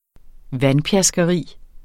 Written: vandpjaskeri

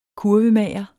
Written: kurvemager

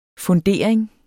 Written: fundering

